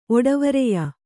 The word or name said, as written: ಒಡವರೆಯ